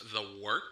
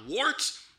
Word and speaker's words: Wort: It is The Wort. Not wart, not fort, not short. The Wort.